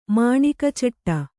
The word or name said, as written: ಮಾಣಿಕಚಟ್ಟ